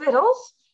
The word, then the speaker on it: victual: And so my 2-year-old also must ask, "What are vittles?" And then I feel the need to explain to my elder children that the word is actually spelled V-I-C-T-U-A-L, but we pronounce it vittle.